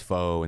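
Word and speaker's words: phở: She likes pho and things. She likes to experiment. – It's pho. Yeah. It's actually pho.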